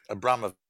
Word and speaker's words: Abramowicz: Uh, Danny it's pronounced by the way. – Abramowitz. – Abramowitz. Abramovich. – Abramovich. – Abramovich.